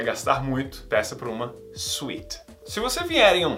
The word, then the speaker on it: suite: Caso você leia suite, primeiramente vamos para a pronúncia. A pronúncia é suite. Suite. Igual a pronúncia de doce. A suite não é a suíte do Brasil.